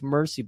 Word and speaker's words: Merced: And finally, Mercy, his name was Mercy, but it's spelled M-E-R-C-E-D, so it was confusing me.